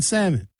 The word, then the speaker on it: salmon: Don't pronounce the L in salmon.